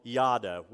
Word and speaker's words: yada: In fact, in the Hebrew language, in the old language of the Old Testament written in Hebrew, there's a word for know called yada, Y-A-D-A is pronounced.